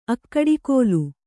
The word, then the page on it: ಅಕ್ಕಡಿಕೋಲು: akkaḍikōlu; kannada